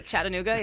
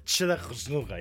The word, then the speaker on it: Chattanooga: This is how it's pronounced? – Chattanooga, yeah. – This is amazing. It looks like it should be like 11 syllables word, but yeah, Chattanooga, yeah.